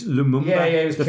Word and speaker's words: Lumumba: He probably was referring to Patrice Lumumba, first Congolese president.